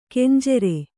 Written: ಖಂಜರಿ

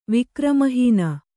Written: ವಿಕ್ರಮಹೀನ

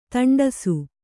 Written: ತಂಡಸು

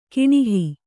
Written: ಕಾಣುಹ

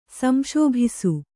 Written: ಸಂಶೋಭಿಸು

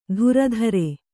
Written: ಧುರಧರೆ

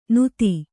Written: ನೂತಿ

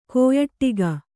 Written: ಕಾಯಡಕೆ

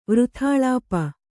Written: ವೃಥಾಳಾಪ

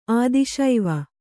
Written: ಆದಿಶೈವ

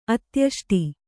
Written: ಅತ್ಯಷ್ಟಿ

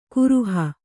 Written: ಕರಹ

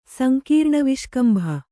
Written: ಸಂಕೀರ್ಣವಿಷ್ಕಂಭ

